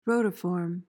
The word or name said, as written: rotiform